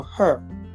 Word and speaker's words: Hur: So the two people, the two people that were holding up the hands of Moses were Hur, H-U-R, that's the way I can pronounce it, Hur and Aaron.